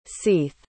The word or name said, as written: seath